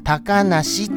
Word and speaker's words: 小鳥遊: 小鳥遊ぶと書いて、高梨って読むのでございますよ。